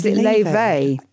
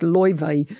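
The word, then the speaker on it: Laufey: I think it's Leife. Yeah. – There's a great clip of her trying to teach a load of actors how to say it on Graham Norton. And she says it's impossible because in Icelandic it's almost Loive, but you can't.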